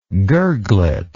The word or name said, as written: gurglet